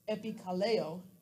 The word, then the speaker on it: epikaleo: The phrase there, will call on, is pronounced epikaleo. And it's spelled E-P-I-K-A-L-E-O.